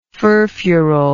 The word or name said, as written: furfurol